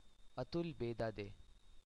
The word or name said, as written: Atul Bedade